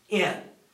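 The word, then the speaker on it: in (ἐν): It actually comes from the Greek word en, which, are you ready for this? It's pronounced in. En in the Greek is in.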